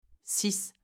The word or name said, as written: 6